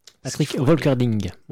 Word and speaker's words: Patrick Volkerding: Patrick Volkerding. On va le prononcer comme ça.